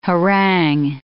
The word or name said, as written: harangue